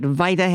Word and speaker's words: Vita: And she did a commercial for, I think I'm pronouncing the herring right, Vita Herring, V-I-T-A Herring.